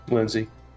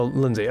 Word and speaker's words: Linsay: How do you fucking pronounce her name? – Lindsay. – It is just Lindsay? Okay. Mm-hmm. But there's no D in it. It's just L-I-N-S-A-Y.